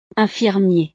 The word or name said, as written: infirmier